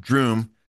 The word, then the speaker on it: Droom: And Doctor Strange is the latest incarnation of a guy that started out as Dr. Droom, not Doom, but Droom, rhyming with broom, who became Dr. Druid and then Doctor Strange.